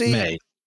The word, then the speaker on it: Mai: It is May. It's spelled like Mai to us, but it's pronounced May.